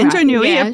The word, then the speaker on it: Indra Nooyi: So Indra Nooyi, if I'm pronouncing it correctly. – Yeah, Indra Nooyi of Pepsi.